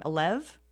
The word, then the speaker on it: Alev: Alev? – That's right.